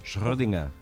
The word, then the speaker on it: Schrödinger: Schrödinger. – ¿Ves? – Que lo diga Schrödinger, claro. – No se puede llegar a eso. – No se puede llegar a ese nivel. No, el gato de Schrödinger.